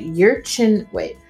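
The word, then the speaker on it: Yurchenko: and I'm going to mispronounce this, but it's the Yurchino double pike vault in competition, okay? Yurchinko double pike vault in competition.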